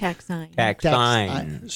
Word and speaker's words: taxine: Taxine. – So how do you spell it? T-A-X-I? – T-A-X-I-N-E. – It's just that it is, you say it differently.